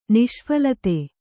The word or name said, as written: ನಿಷ್ಫಲತೆ